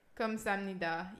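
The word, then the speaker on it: Kamsahamnida (감사합니다): Kamsahamnida.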